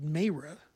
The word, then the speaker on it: Mayreh: Before that, we heard Horace Silver composition called Mayra, M-A-Y-R-E-H, is how it's spelled.